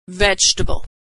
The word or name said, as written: vegetable